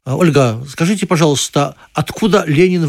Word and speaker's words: Ленин: Назвали в честь русского революционера Владимира Ильича Ленина. Правда, на свой латиноамериканский манер в Эквадоре его зовут Ленин с ударением на последний слог.